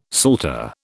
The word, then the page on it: psalter: British English